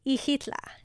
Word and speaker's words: Ixitla: So what we did from here, we went to a little town called Ixitla, spelt with an X at the start, right? – Ixitla. – Very good.